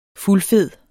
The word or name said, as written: fuldfed